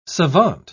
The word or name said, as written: savant